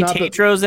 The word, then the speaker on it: Tatro: And Jimmy Tatro's in it. Apparently, that's how you say his name, Tatro. I thought it was Tatro forever.